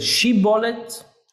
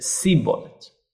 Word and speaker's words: Shibboleth: One of them said Shibboleth, and one of them said Sibboleth.